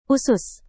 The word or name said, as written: usus